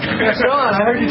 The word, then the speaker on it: Gaston: Is that how you pronounce the name, Gaston? – Mm-hmm.